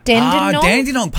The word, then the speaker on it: Dandenong: Dandenong, is that how you say it here? – Dandenong? – Dandenong? – Dandenong, part of Melbourne.